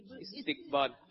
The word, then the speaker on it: استقبال: درست تلفظ کا انتخاب کریں، استقبال یا استقبال؟ – استقبال. – آپ نے کہا استقبال، جواب درست دے دیا ہے.